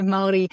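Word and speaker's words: Māori: So the Maori language, so it's Maori is how it's pronounced. And so the R is like a D sound.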